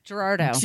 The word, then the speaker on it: Girardeau: Girardeau. – Girardeau.